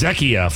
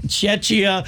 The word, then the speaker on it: Czechia: They'll be taking on South Africa, and then it is South Korea and Czechia. Is that how you say it, right? – Asking the wrong guy. – If you listen to our geography, It was Czechia for a while there. – Czechia, that's a 10 tonight.